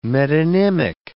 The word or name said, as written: metonymic